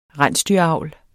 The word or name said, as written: rensdyravl